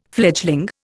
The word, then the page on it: fledgling: Indian